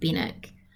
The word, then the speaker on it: Benwick: And when we're talking about the pronunciation of Bennick, there's a W in there, but it's not pronounced, which is, I'm guessing, an English thing.